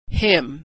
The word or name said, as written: hymn